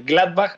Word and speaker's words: Gladbach: Al regresar del corte también comentamos toda la actividad en la Bundesliga, la victoria del Dortmund, el empate del Leverkusen, la victoria del Gladbach, ahora sí lo dije bien,